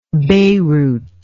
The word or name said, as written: Beyrouth